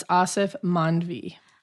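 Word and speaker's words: Aasif Mandvi: And how do you say his name again? – It's Asif Manvi. – Asif Manvi. Mm-hmm.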